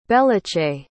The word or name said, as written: bellachay